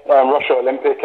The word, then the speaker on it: Rushall Olympic: So before you come to Fulham, you started off at Rushall Olympic. Is that how you say it? – Yeah. Rushall Olympic.